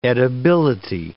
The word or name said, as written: edibility